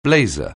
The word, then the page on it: blazer: anglais